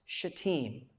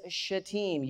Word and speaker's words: Shittim: It is pronounced shatim, okay? In the Hebrew, it is shatim. You're pronouncing it the other way, you're just cussing for fun, okay?